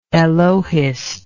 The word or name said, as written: elohist